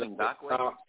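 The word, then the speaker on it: Ngakoue: but Ngoku. He's the one to the logo, to the Chiefs logo, for their pregame meeting. – I think it's pronounced Ngakwe.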